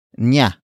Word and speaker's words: niya: The next word is nya. Nya. Nya. Nya is spelled as N-I-Y-A.